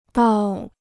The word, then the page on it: 到: dào